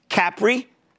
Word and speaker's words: Capri: Capri, which, by the way, that's how they pronounce it over there.